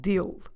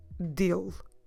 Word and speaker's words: dele: and we get the word dil. So, I like him is eu gosto dil. Como se escreve DIL? D-E-L-E. DIL. Eu gosto DIL. Don't forget to drop completely the last E of the word.